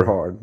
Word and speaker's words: Eberhard: Is that how I pronounce it in English? – I don't know. I suppose so. – Because we've podcasted before. – Eberhard, maybe. – Eberhard. – Maybe Eberhard. – Both sounds equally cool or and strange.